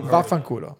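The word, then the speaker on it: vaffanculo: What's Fafangula? Am I saying that wrong? – Fafangula.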